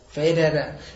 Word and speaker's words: Federer: Federer.